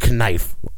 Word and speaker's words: knife: it's knife. You know what I'm saying? Like you, you know you mean knife. The first K is silent.